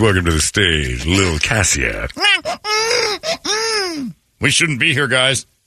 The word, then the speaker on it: Kasia: Keisha Shelton. – Spell that. Yeah, fine. Spell it. – K-A-S-I-A. Or Cassia. – Cassia, yeah.